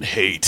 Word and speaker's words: Haidt: It's spelled H-A-I-D-T. – Jonathan Haidt.